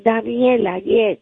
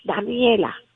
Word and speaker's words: Daniela: Daliela? – Daniela, yes. – All right, I'm doing my best to write it down. I'm doing it phonetically. And I'm going to try to say it again, and you tell me if I'm okay. Daliela. – Okay. Yes, Daniela.